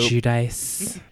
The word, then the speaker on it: Giudice: Like, she's definitely the Teresa Giudice, or however you say her name, of the group. – Giudice.